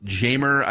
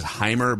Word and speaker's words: Jeimer: Okay, what about, and I've heard it pronounced Jamer. I think the correct pronunciation is Heimer, but we'll, so the American way to pronounce it is Jamer Candelario.